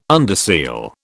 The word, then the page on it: underseal: British English